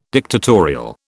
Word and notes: dictatorial: British English

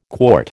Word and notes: quart: American English

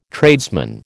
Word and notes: tradesman: American English

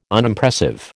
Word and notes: Unimpressive: American English

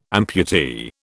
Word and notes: amputee: British English

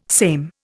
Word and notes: same: Indian

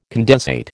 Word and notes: condensate: American English

